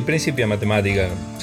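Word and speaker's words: Principia Mathematica: Sí, Principia Matemática se puede decir también llevado al castellano porque no tiene una traducción literal, es más que nada un juego... – Ajá, la H esa es la que está fuera del latín, ¿no? – Exactamente, está fuera del latín, o sea que se puede pronunciar libremente.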